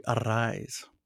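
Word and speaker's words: Arráez: Yep, uh, and uh, he uh, he does the rolling of the R's with a double R. So uh, it, when they announce him, they say Louis Arise. So that's the right way to pronounce his name.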